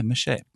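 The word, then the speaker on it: mâché: Is that how you pronounce it? – Mount Everest? – No, mache. – Oh yeah, mache. – Okay. – Yeah, I think so. – Okay. – That's go ahead. – French. All right.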